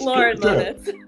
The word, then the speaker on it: Lauren Moniz: And our guest, special guest, is Lorraine Moniz. Did I pronounce that right? – Not even a little bit, but I like the flair. – Okay, well, tell us. – Lauren Moniz. – Lauren.